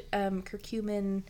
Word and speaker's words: curcumin: Anyway, there is, like, actual science to back up like turmeric and curcumin. I don't even know if I'm saying it right, guys. – Yeah. – Sounds right.